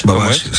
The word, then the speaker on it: Babbage: ¿Cómo se pronuncia? ¿Babbage? – Babbage, sí. ¿Babbage? Sí, así es.